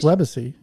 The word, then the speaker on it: Webacy: Which a lot of people can't pronounce. – Legacy, just Webacy, you know? – So. Exactly. It's like Web Legacy. – Yeah. Right? – Makes sense. Webacy, we get Webacy.